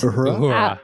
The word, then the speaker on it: Uhura: This is the first episode that I've seen Uhuru. That's hard to pronounce. – Uhura.